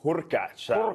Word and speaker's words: Hurkacz: Hubert Hurkacz.